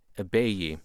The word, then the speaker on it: Ibeyi: They go by the name Ebeyi. – How do you spell that? – I-B-E-Y-I. Ebeyi.